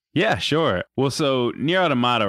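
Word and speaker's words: NieR: Automata: But But Nier Automata came out about a month ago, which is roughly how long it's taken me to learn to pronounce it Nier Automata instead of Nier Automata.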